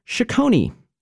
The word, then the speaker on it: chaconne: The Italians would call that a chaconne, but in Baroque England, they pronounced it chaconne.